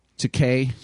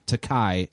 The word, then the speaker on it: Takei: But George Takei, he makes a point to like teach the world how to pronounce his name. It's not Takei, it's Takei, which rhymes with toupee.